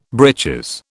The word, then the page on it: breeches: British English